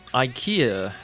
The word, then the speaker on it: IKEA: 说实话呢，这个第一次看到宜家是在那个电影那个和沙漠的五百天，就 Five Hundred Days with Summer。那么 Tom 和 Summer 那个时候就在宜家里面逛家具。So um, IKEA sounds like idea, right? 开头那个 i 都是发 i，不是不是说 IKEA，because the first letter is I. So remember this IKEA.